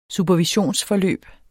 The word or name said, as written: supervision